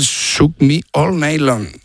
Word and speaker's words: Shook Me All Night Long: Shoot Me All Night Long. – Ah, sí. Lo has dicho en francés. – O sea, has cambiado cuatro palabras de sitio. – Has engolado tanto que se te ha pasado de idioma. – Qué grande, tío. – Me cuesta el Shook Me All Night Long. – Ahí muy mucho mejor.